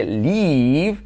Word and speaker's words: leave: Por ejemplo, marcharse, leave, se escribe con EA y se pronuncia con I.